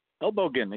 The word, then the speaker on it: Elbogen: Elbow again, exactly right. – Perfect. As, as in the German word, so Thank oh, you, sir.